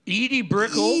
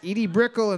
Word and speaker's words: Edie Brickell: We're looking for What I Am by Edie Brickell. – Edie Bricknell and the New Bohemians. Edie Brickell and the New Bohemians.